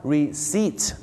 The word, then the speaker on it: receipt: บ้านเราชอบออกเสียงว่า receipt นะฮะ receipt ไม่ใช่ receipt นะฮะก็จะออกเสียงว่า receipt นะครับผม receipt นั่นเองนะ